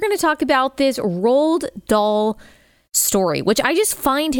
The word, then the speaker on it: Roald Dahl: So it's Roald Dahl, but I might have a hard time.